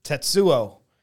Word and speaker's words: Tetsuo: I came up with our idea, and I decided to choose Tetsuo, the Iron Man. – That's how you say it.